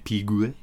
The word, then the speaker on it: Piguet: Audemars, is that an Audemars Piguet? – I never know how to pronounce it. Piguet? – Piguet.